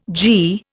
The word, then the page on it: g: Indian